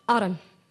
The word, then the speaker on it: autumn: Autumn. – And what's the silent letter? – N.